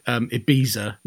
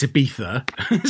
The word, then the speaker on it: Ibiza: So obviously you guys don't hear it so much there, but obviously over here, if there's a C or a Z in a word, it's a th sound. So you get people who call it Ibiza. No, it's Ibiza, spelt with a Z.